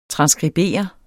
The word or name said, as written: TRANSSKRIBER